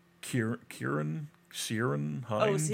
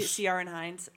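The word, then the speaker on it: Ciarán Hinds: Kieran Hines? – Oh, Ciaran Hines, I think is how you say it.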